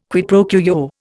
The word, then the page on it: quid pro quo: Indian